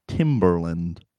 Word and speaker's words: Timberland: In the full word, it's Timberland. The bee is pronounced.